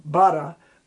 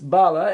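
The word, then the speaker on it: Ballagh: In Japanese, they pronounce his name Bara, but it's Bala.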